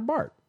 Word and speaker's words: Barth: It's spelled B-A-R-T-H. – Barth. – You're Spanish. You are accustomed to enunciating every single letter that's out there. – Because if you're going to put it there, that's why I'm going to say it. – Well, in many countries, in many languages, there are silent letters.